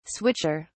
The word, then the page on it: switcher: British English